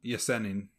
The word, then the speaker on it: Yesenin: Yesenin.